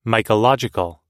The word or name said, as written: mycological